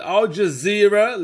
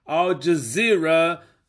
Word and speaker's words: Al Jazeera: See, ladies and gents, as you heard from Al Jazeera, let me correct my pronunciation, Al Jazeera Network, you know.